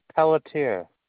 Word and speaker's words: Pelletier: Last name is Pelletier, and that's spelled PELLETIER.